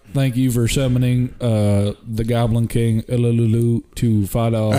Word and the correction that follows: Uluru: Actually, it's more Uluru.